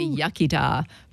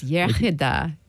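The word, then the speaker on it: iechyd da: Yeah, the English say yachida, but it's yachida, good health.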